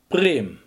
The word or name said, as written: priem